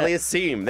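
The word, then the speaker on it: Aliassime: How do you say his last name? – Oje Alisme. – No, yeah. Alisim. There it is.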